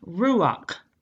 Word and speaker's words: ruach: That word spirit, it's a Hebrew word, and it's pronounced ruach. It's R-U-A-C-H.